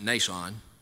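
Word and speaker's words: Mnason: And then that old disciple that we mentioned toward the conclusion of the service over in Acts 21, spelled M-N-A-S-O-N, I just pronounce it Nason.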